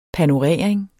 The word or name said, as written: panorering